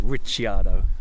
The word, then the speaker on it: Ricciardo: We pronounce it Ricciardo because he's Italian. It's an Italian name, but he's Australian, and he had to change it when he was at school because the Aussie kids couldn't pronounce it. It really annoys me. – You can ignore. It's Ricardo.